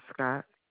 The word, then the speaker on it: Scott: It's Demetria Scott. – Will you spell that for me? – D-E-M-E-T-R-I-A. Last name Scott, S-C-O-T-T.